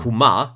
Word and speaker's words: Kumar: Kumar, genau. Kumar ist die Betonung.